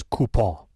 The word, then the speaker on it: coupon: We have a difference of opinion in our family on how to pronounce coupon. Some of us say coupon and some say coupon. – Coupon. – And we don't know if that's a regional thing. – The correct answer is coupon.